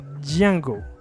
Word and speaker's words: Django: Se escribe Django, pero se pronuncia Django.